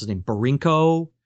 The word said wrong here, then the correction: Bierko: He was Bierko.